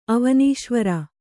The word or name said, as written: ಅವನೀಶ್ವರ